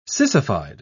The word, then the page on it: sissified: British English